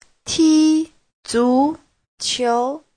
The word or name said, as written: ti1zu2qiu2